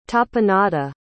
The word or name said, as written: tapenade